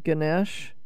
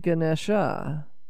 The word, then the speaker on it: Ganesha: I'm going to ask you again about pronunciation, because many people say Ganesh, but you said Ganesha. So is that Ganesha? Is that the proper pronunciation? Because I know a lot of times in Sanskrit there are A's that aren't pronounced, that are silent. – Yeah, it can go either way. It depends on the region of India that you're from. In the south, we usually say the A.